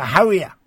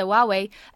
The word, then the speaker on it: Huawei: Now, what's your opinion, asks a listener, of the Howie phones? – The Howie is, or the Huawei.